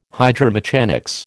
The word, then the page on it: hydromechanics: American English